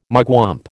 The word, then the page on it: mugwump: American English